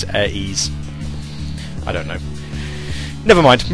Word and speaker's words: Air 3S: Late to the party, Silver Sonic says its name is pronounced Air Ease. Sleet speak.